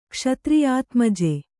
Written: ಕ್ಷತ್ರಿಯಾತ್ಮಜೆ

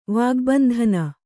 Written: ವಾಗ್ಬಂಧನ

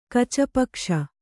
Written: ಕಚಪಕ್ಷ

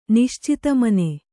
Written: ನಿಶ್ಚಿತಮನ

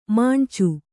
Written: ಮೊನಚು

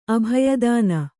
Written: ಅಭಯದಾನ

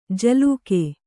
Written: ಝಿಲ್ಲಿಕೆ

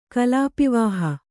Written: ಕಲಾಪಿವಾಹ

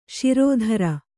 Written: ಷೇರುದಾರ

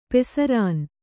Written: ಪಸರಣ

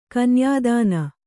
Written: ಕನ್ಯಾಧನ

